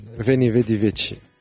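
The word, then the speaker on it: Veni, vidi, vici: لحظة صلحوا لي ليلة باش تعرفوها فيني فيدي فينشي فيني فيدي فيتشي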